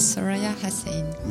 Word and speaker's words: Soraya Hassaine: Hello, my name is Soraya Hassaine.